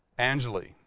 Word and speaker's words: Angley: I never know how to say his name. Angley. There you go.